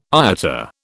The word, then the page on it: IATA: British English